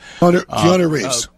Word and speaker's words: Keanu Reeves: Keanu Reeves.